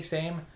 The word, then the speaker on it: SAMe: Is that how you pronounce it? SAMe? SAME? – SAMe. Yeah.